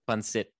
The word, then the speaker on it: pancit: Adobo or pancit? Is that how you say it? – Pancit. – Good, yeah. Pancit.